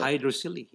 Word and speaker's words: hydrocele: Hydrocele, yeah.